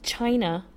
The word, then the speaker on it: Chinah: Opened up with China. I'm thinking that's how we're going to pronounce it. It's spelled C-H-I-N-A-H.